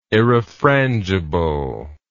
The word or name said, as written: irrefrangible